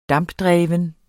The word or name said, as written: dampdreven